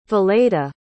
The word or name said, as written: valeda